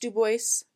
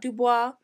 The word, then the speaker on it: Dubois: And before any of you come at me, it is pronounced Dubois, not Dubois.